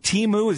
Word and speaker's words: Temu: Is it Temu? Is that correct? – Yeah, that's correct.